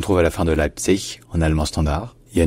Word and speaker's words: Leipzig: Avec dans les deux cas, bien sûr, l'accent tonique qui est sur la première syllabe. Leipzig, bien Leipzig. Mais jamais avec un g à la fin, parce qu'en allemand, le g final se devient toujours un que.